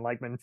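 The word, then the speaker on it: Lachman: It's, but yeah, I'm a big Dyken Lykman fan. – Is that how you pronounce it? – Yeah, Lykman. How do you pronounce it? – That's right. Yeah. German descent originally.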